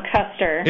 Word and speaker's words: Koester: Is it Koester? – No, it's Koester.